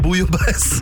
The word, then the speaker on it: Bouillabaisse: Bolabos er sånn uttals. – Bouillabaisse. – Bouillabaisse.